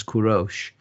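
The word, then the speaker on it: Kurosh (کوروش): In how we pronounce it in Persian is Kurosh.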